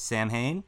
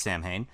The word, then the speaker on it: Samhain: Okay, traditionally, you know, many people know Halloween started as a Celtic celebration known as Samhain, which is sometimes mispronounced as Samhain.